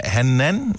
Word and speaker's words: Hanan: Hanan, måske udtales det fra København. Godmorgen, er det rigtigt sagt? – Korrekt.